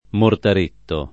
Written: mortaretto

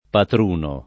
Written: Patruno

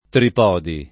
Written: Tripodi

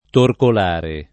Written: torcolare

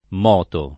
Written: moto

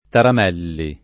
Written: Taramelli

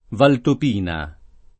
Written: Valtopina

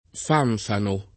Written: fanfano

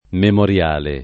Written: memoriale

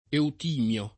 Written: Eutimio

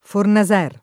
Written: Fornaser

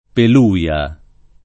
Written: peluia

